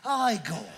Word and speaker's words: Igor: No, it's pronounced Igor.